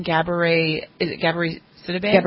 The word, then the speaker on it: Gabourey Sidibe: And then VH1 had one with, how do you say her name, Gabourey Sidibe? – Yeah.